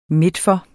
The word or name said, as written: midtfor